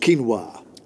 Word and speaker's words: quinoa: Me too, with the quinoa. Pronounced quinoa.